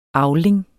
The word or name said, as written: avling